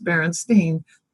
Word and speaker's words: Berenstain: And to me, that's very odd, because when I was reading books, I would be aware, like, is it Stein or Stein? It was never a matter of it's always Stain. There was no confusion. Like now with Stain, there's no other way to pronounce the word, whereas with Berenstain, there was. But now, no problem. It's just Berenstain.